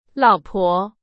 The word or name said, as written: 老婆